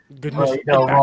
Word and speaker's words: Genshin Impact: Genshin Impact.